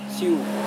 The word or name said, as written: siu